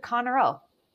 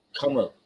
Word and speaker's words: Conroe: So would it be C-O-N? – Like Mike Rowe. Like Mike Rowe. – Okay. Yeah. – Okay. R-O-E? – Yeah, exactly. – Fucking fight me. – Conroe.